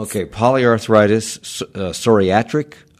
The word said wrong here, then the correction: psoriatic: arthritis. – Yeah, psoriatic.